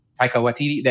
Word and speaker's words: Taika Waititi: But they forget the that boy man whose name I don't want to mispronounce, Waititi. – Taika Waititi.